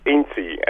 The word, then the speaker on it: Inti: It's Inti, N-T-I, yes.